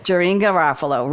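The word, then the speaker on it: Janeane Garofalo: Beth, the camp director, played by, I don't know how she pronounced the name, Janine Garofalo. – Janine Garofalo, right.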